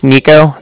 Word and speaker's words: Niko: My name's Nico. That's spelled N-I-K-O.